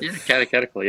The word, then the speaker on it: catechetical: So in light of that, I noticed that you were working on a catechetical, I don't know if I'm pronouncing that right. That word is such a tongue twister. – Catechetical, yeah.